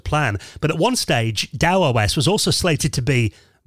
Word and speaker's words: TAOS: I originally thought this was called Tau OS, because I've read this for years. It's spelled T-A-O-S. Turns out Chris teaches me that it's actually pronounced Dow OS.